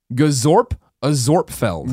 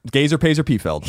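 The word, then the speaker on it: Gazorpazorpfield: It's pronounced Gazorp Azorpfeld. – Nope. Gazer Pazer Pfeld.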